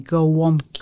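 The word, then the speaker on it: gołąbki: Golomkis. – No, not Golomki. Gowomki.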